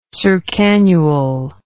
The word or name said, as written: circannual